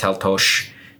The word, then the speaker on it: Taltos: And so the guy's name is Taltosh, T-A-L-T-O-S, pronounced Taltosh, right?